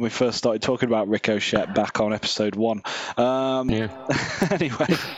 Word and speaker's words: Ricochet: That's how you say it, isn't it? Ricochet. – Yep. Sure.